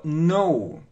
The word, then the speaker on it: know: Вспомним или пройдем это слово know. Пишется вот так. I know. I know. Видите, здесь нужно запомнить, что вот эта буква K не читается в начале, вот в этой комбинации, когда K и N know.